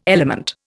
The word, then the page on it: element: Indian